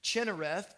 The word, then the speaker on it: Kinnereth: In the Old Testament, the name was Kinnereth, or some pronounced it Chinnereth, perhaps depending on whether or not you say Sibboleth or Shibboleth.